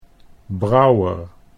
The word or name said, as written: Brouwer